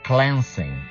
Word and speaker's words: cleansing: Yes, attention to the pronunciation, those of you learning English as a second or foreign language, even though we say to clean, the verb is to clean, but the noun is cleansing. There is a difference, there is a change in the vowel sound.